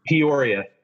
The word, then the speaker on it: Peoria: So, yeah, you were just telling me you are actually in, how do you pronounce it? Peoria? – Peoria. Yeah. Peoria, Illinois.